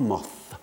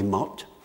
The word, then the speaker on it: Moth: on ne disait pas Moth avec la th, que les Français trouvent tellement difficile à prononcer, mais Moth, que c'est sans doute une sorte de calembour sur la signification du mot mot en français.